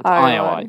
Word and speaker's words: Ayoade: Although, is it Ayoade? It's Ayoade, yes.